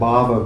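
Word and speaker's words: bhāva: The word bhava in Sanskrit with a short A just means existence, but with a long A, bhava is a state of being or a state of mind, a state of consciousness or an emotion.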